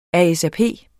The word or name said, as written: ASAP